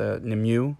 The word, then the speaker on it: Nimue: The lesser known goddess, at least to me, is, I want to say her name is pronounced Nimue, N-I-M-U-E.